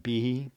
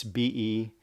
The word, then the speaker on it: Behe: I'm probably going to mispronounce his last name. Behe. It's Behe. Bravo Echo, Hotel Echo.